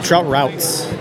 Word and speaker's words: Trout Routes: All right, and you're from Trout Routes or Trout Roots? – Trout Routes. – Some people mispronounce it. I might do it. – I know. So it rhymes, trout and route. So it's Trout Routes.